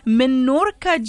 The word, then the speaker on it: Menorca: is it the Menorca gin? Am I pronouncing it correctly? – Yes, you are.